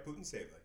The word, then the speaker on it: Putintseva: But Putintseva, how do you say her name? – Yeah, that's right, Putintseva. You got it.